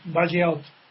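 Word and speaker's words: Bagehot: No, le estás perjudicando porque es Bajiaot.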